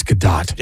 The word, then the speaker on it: Gadot: Oh, it does say it's Gadot.